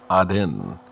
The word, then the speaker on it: Aden: in the Gulf of Aden, or I think it's more properly pronounced, Aden.